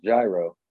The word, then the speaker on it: gyro: Well, I think it's pronounced gyro. – Well, in our context, it is. Otherwise, I think it's a euro.